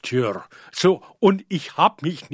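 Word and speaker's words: Tyr: muss man da etwas aufpassen mit der Aussprache. Das klingt fast etwas wie Tür, im Deutschen heißt auch Tür, ja, also dahingehend ist eben dieses Album, ja, das 1990 dann schließlich erschienen ist, schon ein Jahr auf das vorangegangene Album.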